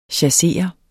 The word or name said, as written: CHASSERES